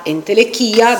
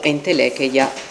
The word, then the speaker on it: entelecheia: Tra la potenza, la dynamis, e la attuazione di questa potenza, di questa possibilità, la entelechia, diciamo, o entelecheia, se lo pronunciamo correttamente alla greca.